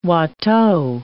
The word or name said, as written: Watteau